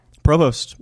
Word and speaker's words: Provost: Provost.